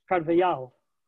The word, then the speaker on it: Carvajal: So I just went in there and listened to, it's Carvajal. Okay, then.